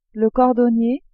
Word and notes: cordonnier: female

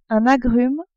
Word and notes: agrume: French

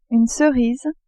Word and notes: cerise: French

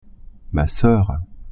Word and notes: soeur: French

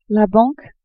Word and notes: banque: female

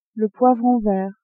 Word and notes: poivron vert: female

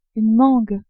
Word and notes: mangue: French

